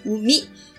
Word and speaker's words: 宇美: 生下王子的地方叫做宇美，宇宙的宇，美丽的美，日文念作五米。跟生产这个字是同音。